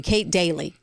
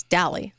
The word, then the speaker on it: Dalley: I get daily, Kate Daily. And I'm like, no, it's Dalley, like Valley with yeah.